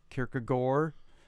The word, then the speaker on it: Kierkegaard: Kierkegaard, to use the Danish pronunciation,